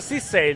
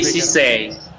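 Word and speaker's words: Szczęsny: Si pronuncia Szczesny il giocatore che è appena entrato, come è stato pronunciato da sì, Szczesny. Sì. Sì, si può dire anche Szczesny, che è credo una mezza punta, sì, Hassan può anche dire se ti viene più semplice.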